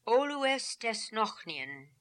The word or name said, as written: Olooestesnokhnian